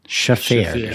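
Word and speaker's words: Shaffir: And then having Ari Streifer. – Shafir. – I can't do this. – He's probably not going to come on the show because I can't pronounce his name.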